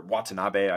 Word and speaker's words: Watanabe: They did lose Seth Curry and Yuta Wannabe, two fantastic three point shooters, or Watanabe, I should say, Yuta Wannabe.